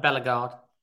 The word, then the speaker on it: Bellegarde: Like for example, that I can't pronounce his name, Bellegarde. Is that correct? – Bellegarde.